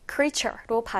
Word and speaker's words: creature: 그래서 어떤 생명체 혹은 생물이라는 의미가 있는데 여기서 특이하게 발음이요. creator가 아니라 마치 a가 없는 것처럼 발음이 됩니다. 그래서 creator가 아니라 creature로 발음이 되기 때문에 이 단어는 미국, 영국 단어가 다 이렇게 발음이 되기 때문에 꼭 기억하면 좋겠어요.